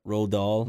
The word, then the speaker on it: Roald Dahl: Is that how you say his name? – Roald Dahl. – Roald Dahl? – Yeah. – Roald, I don't know. – It's Roald Dahl? Yeah, Roald Dahl.